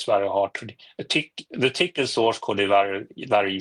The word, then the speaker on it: Tcl: But it should be pronounced Tickle, I think.